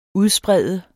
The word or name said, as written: UDSPREDT